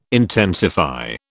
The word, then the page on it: intensify: British English